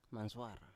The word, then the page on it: mansuar: Biak